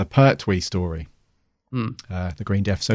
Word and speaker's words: Pertwee: She hates me saying Pertwee. Right, Pertwee. – Pertwee. – Yeah, I have to clearly pronounce the T.